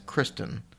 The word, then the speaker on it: Kirsten: It's spelt like Kirsten Cooper, but I could see how some people may say that's Kristen if you say it the right way. But I think it's Kirsten Cooper.